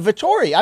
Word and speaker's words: Vettori: He controlled every element of that, that he, he took the heart out of the Lion of Vettori. I mean, that's not wrong. – Yeah, you're absolutely right, and you said it correctly because I mean,